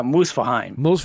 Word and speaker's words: Muspelheim: Now, with the one in, what's it, Muspelheim? How you say it? What's the Fire World? I keep calling it Fire World because I don't know how to say these fucking names. – Yeah, Muspelheim.